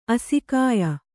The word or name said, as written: ಆಸೆಗೆಯ್